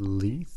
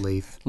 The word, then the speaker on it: Leith: They pronounce it Leith.